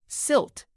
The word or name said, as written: silt